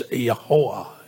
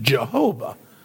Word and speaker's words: Yahuwah: The word is pronounceable. In Hebrew, it's Yahuwah, or Yahuwah. And the German translations pronounce it Jehovah. So they have just taken it and turned it into one of the Western languages that are non-Semitic.